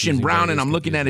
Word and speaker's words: Braun: Yeah, I was confused, too, but I confirmed that it's pronounced Brown.